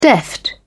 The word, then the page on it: deft: British English